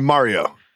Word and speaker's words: Mario: M-A-R-I-O. How do you say that? – Mario. – I say Mario, and I always have, and people always comment on it. And I think it's because I'm from New York.